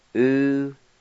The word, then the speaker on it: unesosa: U-N-E-S-O-S-A.